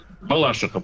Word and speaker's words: Балашиха: у нас город поделился, у нас историческое название Балаших. Ага. Точнее, Балашиха, правильно. – Нет, да. Все-таки правильно Балашиха, прости, пожалуйста.